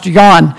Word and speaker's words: Jan: I think of the example God has provided, our missionaries, the Walls, Jan and Annette, J-A-N, pronounced Jan.